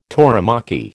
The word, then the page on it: tauromachy: American English